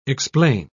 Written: explain

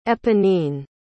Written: eponine

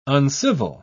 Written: uncivil

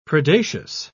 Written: predaceous

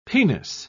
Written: penis

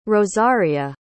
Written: rosaria

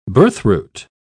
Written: birthroot

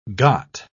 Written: got

